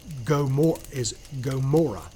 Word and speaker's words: Gomorrah: I have been told that the actual correct pronunciation is Gomorrah. So it's supposed to be a long O first. I've been told that. I've had other people say, no, it's a short O.